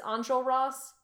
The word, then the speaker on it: Enjolras: the pronunciation says Enjolras. – Yeah. – J feels wrong. I don't know. Okay. I still... – I mean, a J this... in French is j, you know, it's a soft... – Right. This pronunciation I was listening to definitely had it as like a... – Hard. – A DJ sound.